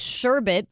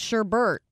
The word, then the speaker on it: sherbet: It's sherbet, but everyone says sherbert.